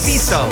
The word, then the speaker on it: Treviso: Am I saying that right? – Yes. – Treviso.